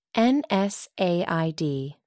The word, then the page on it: nsaid: American English